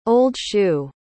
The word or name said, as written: old-shoe